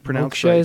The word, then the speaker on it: Moksha: I wanted to make sure I had that pronounced right. – Moksha is correct.